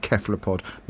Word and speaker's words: cephalopod: as it is from the Latin, who do not have a soft C. So there you go, it's actually cephalopod.